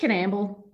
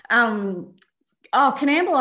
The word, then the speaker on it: Coonamble: No, just Canamble. But you've got to get the accent right. It's spelt Coonamble, but no one actually pronounces it like that. So you've just got to get the accent right. Canamble, I think it's got a real personality.